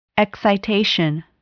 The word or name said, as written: excitation